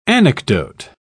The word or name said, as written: anecdote